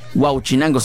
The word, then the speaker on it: Huauchinango: Dice, un saludito muy especial para mi buen amigo el Cachibombo, de parte del Tortuga, que llevamos hacia los 20 a Huauchinango. Huauchinango, sí, lo dije bien. Porque una vez lo pronuncié mal, como si lo pronuncié como si fuera el pescado.